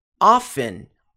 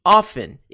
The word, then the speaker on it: often: Lembrando que, often, tem duas pronúncias possíveis. Eu pronuncio often, eu e muitas outras pessoas, é uma pronúncia correta, often. A outra pronúncia correta é often. Então, você pode usar as duas, often ou often, como se não tivesse o T.